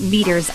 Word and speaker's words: Meaters: Now, there's a few different Meaters on the site. By the way, meaters is spelled M E A T E R S, like meat ers.